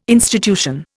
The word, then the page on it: institution: Indian